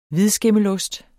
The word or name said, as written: hvidskimmelost